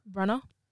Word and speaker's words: Branagh: Branagh.